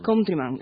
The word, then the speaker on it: Countryman: Hoy la novedad es el mini, a ver si lo digo bien, Countryman. – Countryman, efectivamente. Pues de ahí venimos.